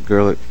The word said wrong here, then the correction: Gerlicher: It's Gerlicher? – Yes, sir. – Okay. – Mr. Chair. – Did I pronounce it right? – Okay. That's correct.